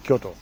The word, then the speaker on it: Kyoto: Kyoto spricht man wahrscheinlich gar nicht so aus, wie ich es ausspreche, oder? Das ist wahrscheinlich sehr deutsch ausgesprochen. – Nö, sehr gut, sehr gut. Kann man so aussprechen. Kyoto.